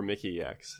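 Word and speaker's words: Mikyx: I mean, well, for comparison, you know, like the MVP for the LCS was Mikix. Am I pronouncing this right at all? – Yeah, Mickey or Mickey X, yeah.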